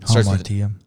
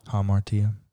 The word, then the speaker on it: hamartia: Hamartia. – Huh? – Hamartia.